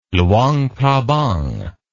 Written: Luang Prabang

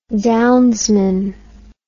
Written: downsman

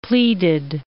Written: pleaded

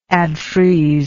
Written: adfreeze

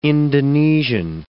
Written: Indonesian

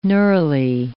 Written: neurulae

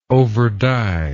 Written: overdye

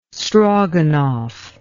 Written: stroganoff

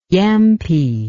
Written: yampee

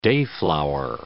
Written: dayflower

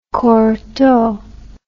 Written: cortot

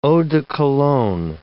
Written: eau de cologne

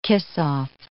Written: kiss-off

